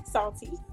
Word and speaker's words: Psalte: So my name is P-S-A-L-T-E. That's me, Salty. I always say that because, you know, the P is silent.